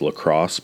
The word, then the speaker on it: LaCroix: I'd be fucking up the LaCroix, which I also watched a video, and the dude said that the area that it's from, it's actually pronounced La Crosse, but I refuse to say that. I'm just going to call it LaCroix forever.